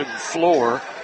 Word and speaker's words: Flor: Justin Floor, spelled F-L-O-R, pronounced F-L-O-O-R.